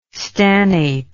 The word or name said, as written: stannate